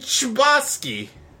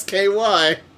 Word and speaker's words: Chbosky: Oh, yeah, it's by Stephen Chbosky. – Oh. – That's probably not how you say his name. It's Chbosky.